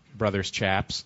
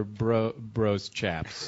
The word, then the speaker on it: Bros Chaps: It's spelled B-R-O-S dot. Is it? Should I pronounce it out loud, brothers Chaps, or bros Chaps? – Bros Chaps. – But yeah, the Bros Chaps. – Okay, like Roz Chast.